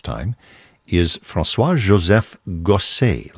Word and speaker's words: Gossec: Last name is spelled G-O-S-S-E-C. You might think that would be pronounced Gosek, but we know that he himself pronounced it that way because he would spell his name differently, sometimes with an E-Z on the end, an E-T, whatever it is.